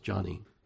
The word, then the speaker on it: Joni: You know, here Joni had to put, and I think really her name's supposed to be pronounced Johnny, because she was named after her dad. Yeah. But a lot of times we say Joni because we look at that word and we think that's how you pronounce that.